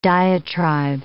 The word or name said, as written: diatribe